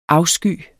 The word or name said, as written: afsky